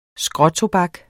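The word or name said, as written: SKRÅTOBAK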